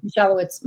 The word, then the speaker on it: Michalowicz: Michalowicz.